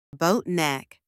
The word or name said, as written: boat neck